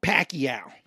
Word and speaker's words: Pacquiao: And then they come back and like, hey Max, I was told it's pronounced Pacquiao.